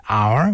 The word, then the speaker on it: hour: Example, the letter H in hour, we don't pronounce H.